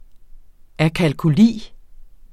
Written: akalkuli

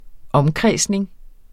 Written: omkredsning

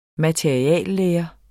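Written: materialelære

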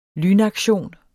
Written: lynaktion

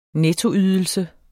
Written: nettoydelse

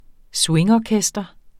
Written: swingorkester

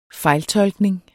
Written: fejltolkning

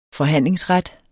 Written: forhandlingsret

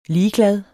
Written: ligeglad

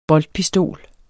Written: boltpistol

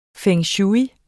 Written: feng shui

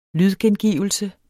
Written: lydgengivelse

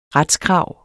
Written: retskrav

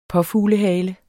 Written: påfuglehale

